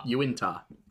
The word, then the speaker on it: Uintah: Uintah, I believe, is the correct pronunciation. I was told.